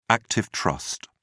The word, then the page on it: active trust: British English